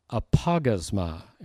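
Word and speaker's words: Apagasma (ἀπαύγασμα): Apagasma is the Greek word. Apagasma. I think I wrote it on your outline, apagasma. The emphasis is, the accent is on the AU part of that word.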